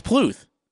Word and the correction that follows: Puth: Charlie Puth. – No, it's Pluth. It's, isn't it P-L-U-T-H?